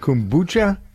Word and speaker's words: kombucha: Kombucha? – Kombucha.